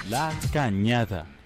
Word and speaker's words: La Cañada: Good Lord, how to pronounce? How to move? No, pronounce La Canadia. Here, wait, let's see. – La Cañada. – La Cañada. See, I had it completely wrong.